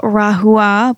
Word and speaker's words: Rahua: The Rahua, which is spelled R-A-H-U-A, which I used to call it Rahua, but it's actually pronounced Rahua.